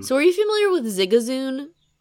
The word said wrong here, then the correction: Zigzagoon: Zigzagoon? – Zigazoon. Is it Zigzagoon? – Isn't it? – You're absolutely right. I've been saying this wrong for, oh.